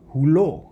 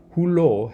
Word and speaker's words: hulō: In terms of pronunciation, hulō has a kaha ko over the o, which means you place the emphasis on that second syllable.